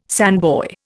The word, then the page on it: sandboy: Indian